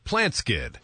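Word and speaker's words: PlantSkydd: Plant Skid. That's spelled plant, S-K-Y-D-D. Plant Skid.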